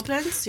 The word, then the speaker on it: Koblenz: I think it's pronounced. – Yeah, Koblenz, yeah.